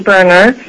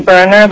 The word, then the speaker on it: Berner: And for the record, my name is Vernay Berner. First name, and for the benefit of folks, is pronounced Vernay, rhymes with Renee. And last name Berner, like a stove burner.